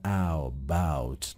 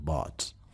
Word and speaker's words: bought: دقت کنید عزیزان که چون OU داره بعضی ها میان قوس میدن و میگن او باوت، نه نه نه، این آ تلفظ میشه bought.